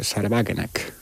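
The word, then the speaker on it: Wagenknecht: Porque hay, para mí, el experimento de partido más interesante que se está dando en la Unión Europea, por cierto, también putinista, es el de Sarbagenek, o como claro, se diga en alemán, ¿no? – Está bien pronunciado, ¿eh? – Sí. Bueno, pensé que me ha salido demasiado sajón, pero vale.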